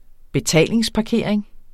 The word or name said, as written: betalingsparkering